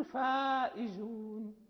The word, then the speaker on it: الفائزون: وهناك كذلك إذا جاء بعدها ألف فيكون التحفظ من تفخيمها، مثلا وأولئك هم الفائزون، نقول الفائزون، لا يعني نفخمها ونستعلي بها، فهي مشتفلة مرققة.